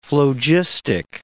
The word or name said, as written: phlogistic